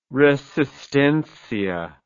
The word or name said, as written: Resistencia